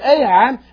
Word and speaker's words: Ai: Now, that word here, Ai, and like I said, if you listen to it, they also pronounce it I, but the name itself means a heap of ruins.